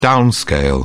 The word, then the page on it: downscale: British English